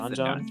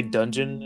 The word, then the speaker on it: Donjon: D-O-N-G-O-J-O-N? – Yeah, Donjon. Donjon. – Okay. I keep wanting to say dungeon. It's just spelt weirdly. – See, I first thought it said Don Juan.